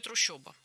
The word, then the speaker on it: трущоба: Ну, нам, наверное, не так-то принципиально, как то, что там действительно пишется «о». Как и в слове «трущоба». Хотя слышится очень ёшно, по-моему.